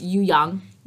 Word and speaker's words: Ouyang: Ouyang? Is that how you pronounce it? – The eunuch? – Yeah. – Ouyang. – Ouyang. – Yeah. Oh, sorry. It's Ouyang.